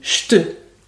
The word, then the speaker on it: je te: Pour la séquence « je te », on dit « j'te ». Par exemple, « je te cherche partout », on dit « j'te cherche partout ». On ne dit pas « je te cherche partout ». Non, on dit « j'te cherche partout ». C'est invariable, la prononciation est fixe.